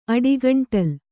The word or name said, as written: ಅಡಿಗಂಟಲ್